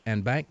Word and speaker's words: en banc: What they're saying in here is there's a possibility now that the Ninth Circuit wants to view this en banc.